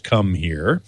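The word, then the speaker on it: Comhear: And here it is now in a new form factor from a company called Come Here, C-O-M-H-E-A-R. I think it should be pronounced Come Here.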